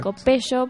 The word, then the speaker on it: Copello: L, sí, Cande Copello para los que no sepan cómo pronunciar.